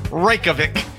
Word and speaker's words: Reykjavik: Reykjavik. – You're getting there. – I spell it like rake. Reykjavik.